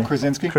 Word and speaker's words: Krasinski: Krasinski, yeah.